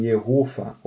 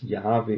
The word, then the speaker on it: Jahwe: Viele sagen Jehova oder andere sagen Yahweh. Sie haben so wissenschaftlich gesehen, ist es eher wahrscheinlich, dass es Jahwe ist, die richtige Aussprache, weil das Wort Jahwe hat eine Bedeutung. Diese Bedeutung, ich bin. Und das Wort Jehova hat keine Bedeutung.